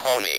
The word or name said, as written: pony